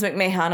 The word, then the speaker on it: McMahon: Is that actually pronounced McMahon? – Yeah, Vince McMahon.